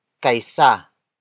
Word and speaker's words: kaysa: The next word is kaisa. Kaisa. Kaisa. Kaisa is spelled as K-A-Y-S-A.